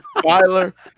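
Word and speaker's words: Feiler: Feeler? – There you go. Dollar. Filer. – It's Filer. See, there you go.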